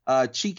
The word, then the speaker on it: Chike: Chike Okebu, first and last name on Twitter.